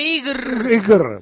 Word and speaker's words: Igor: Este salame no se dice Igor, se dice Igr.